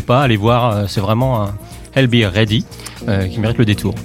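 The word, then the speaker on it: Ailbhe Reddy: A-I-L-B-H-E et plus loin R-E-D-D-Y. – Bon, donc, ça se prononce comme ça. – I'll Be Ready.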